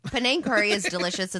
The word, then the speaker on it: Penang: Penang curry? – Mm-hmm.